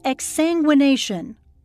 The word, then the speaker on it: exsanguination: It's called exsanguination, Am I saying it correctly? – Yes.